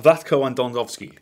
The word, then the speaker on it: Vlatko Andonovski: Vla- Vlatko Andonovski.